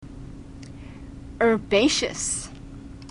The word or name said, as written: herbaceous